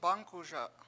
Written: bAngkuSa

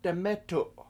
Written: demEtu7

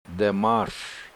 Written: da7mAAS

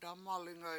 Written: dAmalinga7

